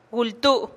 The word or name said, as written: gul tuu' ya